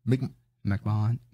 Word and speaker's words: McMahon: McMahon.